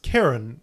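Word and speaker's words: Charon: It's pronounced Karen, which is basically Kieran's name.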